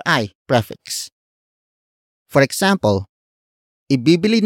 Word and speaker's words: ibibili: The first word is ibibili. Ibibili. Ibibili. Ibibili is spelled as I-B-I-B-I-L-I.